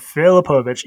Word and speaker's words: Filipovic: I did not know how to pronounce it until right this moment, but yes, I do. – Filipovich.